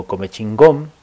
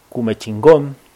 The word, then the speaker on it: Comechingón: Así que, bueno, vamos a conversar en esta oportunidad con una dirigente de ahí de Córdoba, de una comunidad del pueblo Comechingón, o Cumechingón, porque bueno, a veces pronunciamos justamente las cosas o los nombres tal como lo hemos leído, no sé, en algún manual o en algún libro que justamente ha entregado el Estado, y que muchas veces no es del todo de esa manera, ¿no?